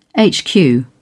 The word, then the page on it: hq: British English